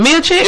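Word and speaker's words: Miočić: Is it Miocic? – Yeah, you said it right.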